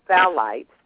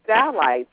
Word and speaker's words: phthalates: You have another one that's called, I'm going to see if I can pronounce this right, the one I told you about, phthalates, is actually spelled P-H-T-H. And phthalates they found a lot of plastics in them, and they're using solvents.